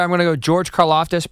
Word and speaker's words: Karlaftis: Karlaftis. – God bless you. – George Karlaftis.